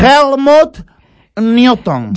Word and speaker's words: Helmut Newton: Tenemos a la Helmut Norton. – ¿Cómo? – Le gusta mucho cómo pronuncio. ¿El qué? – Es que no le van a entender. – Helmut Newton.